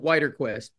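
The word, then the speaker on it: Widerquist: Actually, I pronounce it Wiederquist, but...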